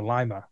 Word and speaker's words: Lima: Dan Bromley, AFT, American Flat Truck Racer, passed away from his injuries at an accident at Lima over the weekend, or Lima, I guess it's pronounced, over the weekend.